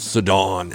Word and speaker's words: Sidon: There's this guy that reads the Audible Bible, and he always says Sidon, which is how I pronounce it, Sidon, and it sounds so much loftier and cool.